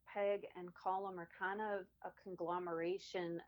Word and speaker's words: Colm: but Colm or Colm? – C-O-L-M. – Say it again. – Colm.